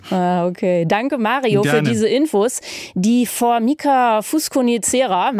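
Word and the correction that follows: fuscocinerea: macht sich in Mitteleuropa breit. Ich habe es garantiert nicht richtig ausgesprochen. – Fusco-Cinerea.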